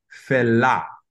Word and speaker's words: Fela: so think of French. – Okay. – Think of the way that an E is pronounced in French. It's pronounced a. – Fela. – And an A is pronounced A-H, like ah. – Feha. Yeah, it's I can't do it. – So no, yes you can. Yes you can. So the pronunciation is fella.